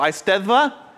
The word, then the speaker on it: Eisteddfa: The road's name is Eisteddfa. DDs are pronounced as the, and Fs sound like Vs. So Eisteddfa, and then it's in the town of Gwynedd.